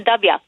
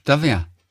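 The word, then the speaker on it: Dubya: Aber dieses W wird im Süden der Vereinigten Staaten nicht W ausgesprochen, sondern Dabia. – Ach, Dabia.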